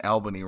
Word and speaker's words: Albany: Am I saying that right? I always feel like I'm saying Albany wrong. – No, you got it. It's Albany. – Sweet. – Right. Actually, how it's not. It's Albany. You? So anyone you talk to, friends, family, and you refer to it, please pronounce it correctly and say Albany.